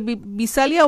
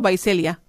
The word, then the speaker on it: Visalia: ¿Cómo se pronuncia? ¿Visalia o Vaiselia? – Vaiselia.